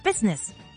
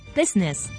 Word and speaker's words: business: 当然呢个字除咗解商业业务之外，亦都有啲人会好冇礼貌咁同你讲唔关你事，That's none of your business。记住啦，busines 应该读成 business。